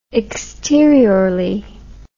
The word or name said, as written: exteriorly